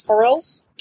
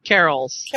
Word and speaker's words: carols: The charmless, how do you say this, corals? – Carols.